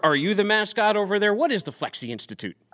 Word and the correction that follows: Flexe: We are Flex with an E on the end, but the E is silent. It's just the Flex Institute.